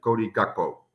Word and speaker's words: Gakpo: Yeah, I think you pronounced it not really okay. It's Cody Gakpo, we say here. So, yeah, but a lot of people also say Cody Gakpo, but the right pronunciation is Cody Gakpo.